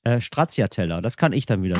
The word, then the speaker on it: Stracciatella: Stracciatella. – Habt ihr gehört? – Stracciatella.